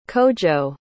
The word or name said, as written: kojo